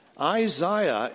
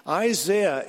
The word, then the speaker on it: Isaiah: The secret is this, that in spite of all my protestations, Isaiah is the English pronunciation. Isaiah is the Irish, Scottish, and American pronunciation.